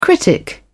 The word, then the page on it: critic: British English